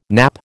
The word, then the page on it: nappe: American English